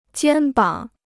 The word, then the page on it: 肩膀: jiān bǎng